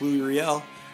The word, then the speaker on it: Louis Riel: So to start off, by the way, I feel very good that it is pronounced Louis Riel.